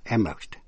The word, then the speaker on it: Amherst: Thành phố Amherst, cũng là tên đại học nổi tiếng, Amherst College of Massachusetts, đọc là Amherst.